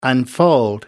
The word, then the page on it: unfold: American English